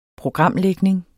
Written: programlægning

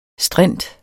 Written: strint

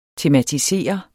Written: tematisere

